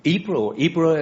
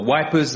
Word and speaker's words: Ypres: Ypres, as the Flemish pronounce it, or Wipers, as the Tommies rejoice in pronouncing it, the most bombed town on the Western Front.